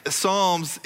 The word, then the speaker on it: Psalms: And some people pronounce it Psalms. They think it's Psalms, but the P is silent, as it always should be in our lives.